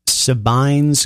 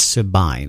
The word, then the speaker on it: Sabine: Before that, Sydney Jacobs starting out the top of the set, and I believe the name is pronounced Sabine's Grind. I think it's Sabine, S-A-B-I-N-E.